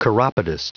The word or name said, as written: chiropodist